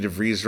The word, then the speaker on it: DeVries: Vicky DeVries rhymes with cheese.